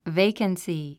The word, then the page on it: vacancy: véikənsi; ベイカンスィ